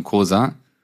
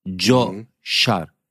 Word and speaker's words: Coşar: Nein, nicht Coşar. Joe. Wie Joker. Coşar. Wie Joker und ein Schar Menschen. Coşar.